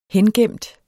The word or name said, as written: hengemt